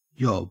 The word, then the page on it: yob: Australian English